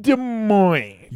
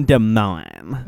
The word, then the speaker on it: Des Moines: All the way from Des Moines. – Yo, I'm not this champagne. – Dude, not incorrect. – From Des Moines. – From Des Moines. – The S is silent.